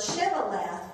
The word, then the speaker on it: Shibboleth: And they would say what? – Sibboleth. – And so it says, for he could not pronounce it correctly.